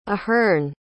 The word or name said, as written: ahearn